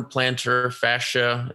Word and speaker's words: plantar fascia: No, but Shams Shenaria is reporting that Heat guard Goran Dragic has suffered a torn plantar fascia. Is that even how you say it? – Yes.